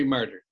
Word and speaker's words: Marder: But in 1993, you, Kim Howard Johnson, and Larry Marder. Am I pronouncing his last name correctly? – Larry Marder. That's right.